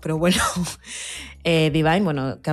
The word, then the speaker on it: Dvyn: Ella es Divine. Lo he dicho así, pero se escribe D-V-Y-N.